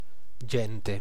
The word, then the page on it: gente: [la ˈdʒɛn.te]